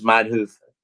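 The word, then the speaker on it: Manhoef: I'll tell you how to say it right. Showing a Dutch. It's Man Hoof.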